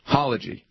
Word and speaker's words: Halaji: Halaji. It's called The Halaji, A Chronicle of Friendship and War in Kabul.